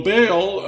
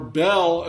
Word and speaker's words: Baal: Well, Baal, and you can look this up on Wikipedia, Baal, or Bel, as it's pronounced sometimes, goes all the way back to ancient Babylon and goes back to an ancient Babylonian deity known as Marduk.